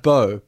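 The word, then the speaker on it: boh: Bo. – They're not saying it right. It's bo. Like, it's like a question.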